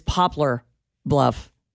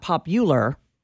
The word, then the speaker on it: Poplar Bluff: It is Poplar Bluff. It is not Popular, just as it is Arkansas City, which should be pronounced Arkansas, but is not.